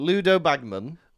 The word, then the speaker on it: Ludo Bagman: Ludlow Baggins? Is it Ludlow Baggins? – No, what's he called? Ludo Bagman.